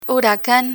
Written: huracan

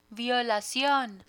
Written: violacion

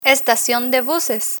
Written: estacion de buses

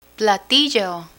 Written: platillo

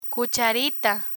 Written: cucharita

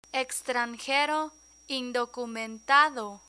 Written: extranjero indocumentado

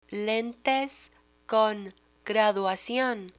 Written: lentes con graduacion

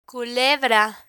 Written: culebra

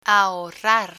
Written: ahorrar

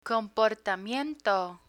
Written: comportamiento

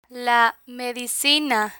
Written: la medicina

